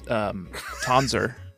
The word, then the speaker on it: Tonzer: My name is Tonzer. – Bronzer? No, because it's spelled with Z-E-R.